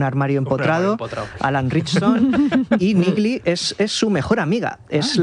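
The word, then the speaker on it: Neagley: Bueno, pues si os parece, vamos con la siguiente, que es Nigli. ¿Lo he dicho bien? – Nigli, sí.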